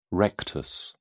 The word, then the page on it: rectus: British English